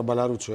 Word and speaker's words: Μπαλαρούτσου: Σαβίνα Μπαλαρούτσου. Αυτό δεν πιστεύω ότι τονίζεται αλλού. – Η Σαβίνα, λοιπόν, ναι. – Ναι. Είναι σίγουρα Μπαλαρούτσου, ε.